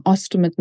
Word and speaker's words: ostomate: And I know that we're going to talk particularly about you being, and is the expression ostomate? Have I pronounced that correctly? – Yes, I am what you call an ostomate now.